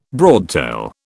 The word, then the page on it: broadtail: British English